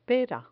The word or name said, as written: pera